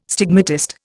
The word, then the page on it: stigmatist: Indian